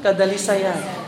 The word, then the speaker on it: kadalisayan: O ito, kadalisayan. Tama ba yun? Kadalisayan. Paano yung sabihin? – Kadalisayan. – Yun ang ano, tamang pronunciation ng kadalisayan.